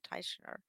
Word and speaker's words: Teichner: Jason Teichner.